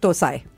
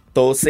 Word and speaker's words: dosa: Dosai. – So dosa is North Indian pronunciation. – Dosa, right? – Right. Mm. Tose is South Indian Tamil pronunciation.